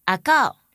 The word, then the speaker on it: 阿猴: 屏东原来是一个原住民发音的地名，它的发音类似于阿告，也有人把中文写成阿猴。